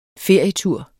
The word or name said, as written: ferietur